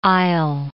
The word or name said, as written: I'll